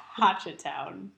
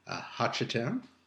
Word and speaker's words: Hochatown: But I think it's Hochatown. – Okay. – Like ha, not like ho.